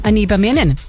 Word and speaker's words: Anibaminan: That's Ojibwe for summer berries. Again, it's pronounced Anibaminan.